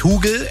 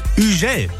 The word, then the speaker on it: Hugel: Er heißt nicht Hugel, er heißt auch nicht Hugel, sondern er heißt Hugel.